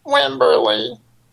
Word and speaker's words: Wimberley: Wimberly. – Okay, and how do you spell that? – W-I-M-B-E-R-L-E-Y.